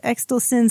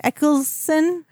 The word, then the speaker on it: Eccleston: It was Chris Exelson. Sorry, I can't say his name. – Eccleston.